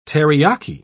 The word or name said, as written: teriyaki